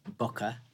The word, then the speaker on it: Boka: It is Boka. – It is, yeah. It's a play on the Italian word Boka. – Boka. – Mouth. To eat, but it's phonetically it's Boka.